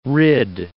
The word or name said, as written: rid